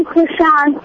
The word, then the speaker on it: Krishan: Spell it. – K-R-I-S-H-A-N.